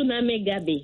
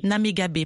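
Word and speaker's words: Namegabe: Est-ce que je prononce bien ? – Chouchou Namagabé. – Namagabé, pardon. Oui.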